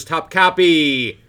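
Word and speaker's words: Topkapi: And we watched one of my favorites, and that is 1964's Top Copy, starring... – You want to spell that for the people? – Yeah, it's T-O-P-K-A-P-I.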